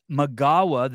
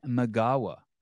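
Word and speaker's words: Migała: Gabby Magawa, the L is pronounced wuh. Gabby Magawa.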